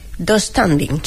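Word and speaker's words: döstädning: Yo lo pronuncio dos standing. – Sí, así. Tengo esa silla del IKEA, sí, se pronuncia así.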